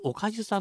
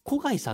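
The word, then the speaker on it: 小粥: 言い伝えて、その家では今でも、読み方はおかゆさんと小貝さんという方もいらっしゃいますけど、今でも名乗ってます。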